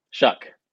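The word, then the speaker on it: Shough: Texas Tech transfer Tyler Show. I'm not sure how you say it. What? – Shuck.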